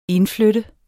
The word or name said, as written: indflytte